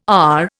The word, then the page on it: AR: Indian